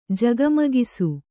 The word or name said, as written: ಜಗಮಗಿಸು